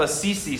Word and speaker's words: Assisi: It's attributed to Saint Francis of Assisi, which is how you say it. That's not how I pronounced that place when I very first read it.